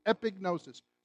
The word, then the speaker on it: epignosis: For the longest time, I said epignosis, and then I asked somebody who knew Greek to pronounce it for me, and they said epignosis. So just think of a pig in the middle of it. You won't go wrong in pronouncing it.